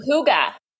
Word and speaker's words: hygge: And I sat down and I um I was introduced to like the concept of I'm going to mispronounce this because I always mispronounce it, but either like Hig or Hig or like whatever that. It's like oh, hygge.